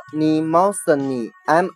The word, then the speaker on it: Mnemosyne: 摩涅莫绪涅，她的英文名字呢读作 Nemosine，M N E M O S Y N E。